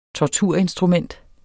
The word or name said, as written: torturinstrument